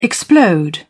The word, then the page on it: explode: British English